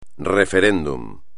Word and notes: referéndum: espagnol

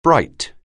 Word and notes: bright: anglais

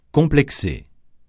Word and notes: complexé: francais

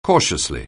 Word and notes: cautiously: anglais

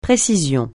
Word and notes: précision: francais

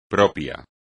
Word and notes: propio: espagnol

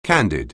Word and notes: candy: anglais

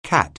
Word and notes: cat: anglais

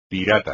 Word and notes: pirata: espagnol

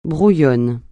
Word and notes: brouillonne: francais

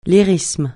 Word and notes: lyrisme: francais

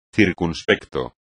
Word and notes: circunspecto: espagnol

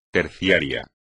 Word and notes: terciario: espagnol

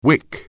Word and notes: wick: anglais

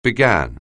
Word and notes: begin: anglais

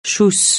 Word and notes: schuss: francais

